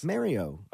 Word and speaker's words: Mario: Who's Marielle Lopez? – Mario.